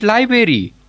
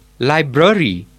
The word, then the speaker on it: library: Not library, it's library. – Okay, sir, library.